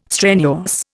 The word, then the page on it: strenuous: Indian